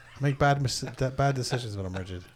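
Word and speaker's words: Ridgid: It's pronounced rigid. – Rigid? – Yeah. – It's rigid.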